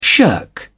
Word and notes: shirk: British English

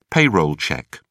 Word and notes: payroll check: British English

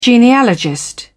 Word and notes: genealogist: British English

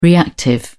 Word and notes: reactive: British English

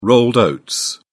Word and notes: rolled oats: British English

